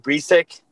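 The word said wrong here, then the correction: Brieske: Is that how you say it? – Breesky. It's Breesky or Brisky, either way.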